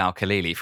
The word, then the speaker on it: Al-Khalili: So, World According to Physics, Jim Al-Kahali. – So I'm pretty sure that I'm right in saying you're butchering his name, and it's Jim Al-Khalili from Radio 4, okay.